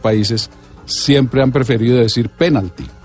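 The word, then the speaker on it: pénalti: Entonces el diccionario de americanismos, que es el nuevo diccionario normativo que tiene la academia, sí recoge la palabra Penalty, esdrújula, con tilde en la E, que es como la pronuncian la mayoría de locutores y narradores en Colombia.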